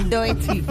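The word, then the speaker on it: Doechii: Doechi.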